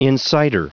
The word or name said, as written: insider